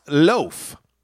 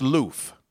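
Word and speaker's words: l'œuf: The egg, which is pronounced loaf. So, and it's actually it's pronounced loof.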